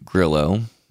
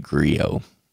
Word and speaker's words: Grillo: But this film stars Frank Grillo, or maybe he pronounces it Griot. I'm pretty sure it's Grillo.